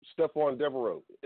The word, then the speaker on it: Stephon Devereux: You know, Stephon Devereux. It's not really the easiest names to pronounce, especially if you don't know that the E is silent.